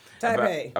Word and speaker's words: Taipei: Taipei.